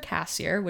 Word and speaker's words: Casier: Okay, I'm on Instagram as, this is one word, the sassier cassier, which is the word sassier, and then my last name, which is C-A-S-I-E-R.